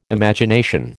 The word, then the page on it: Imagination: American English